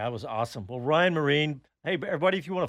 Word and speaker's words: Myrehn: M-Y-R-E-H-N is the last name, and somehow it's pronounced Marine.